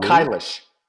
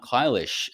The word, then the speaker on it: Kailash: You know, you've been to, you know, India, Nepal, I don't know if I'm pronouncing this correctly, but Mount Kailash and Mount Kailash. – Mount Kailash.